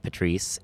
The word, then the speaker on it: Patrice: And Patrice is spelled P-A-T-R-I-C-E. So it's Joe Pat Rice is how I say my name.